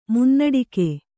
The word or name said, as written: ಮುನ್ನಡಿಕೆ